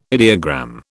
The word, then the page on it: ideogram: British English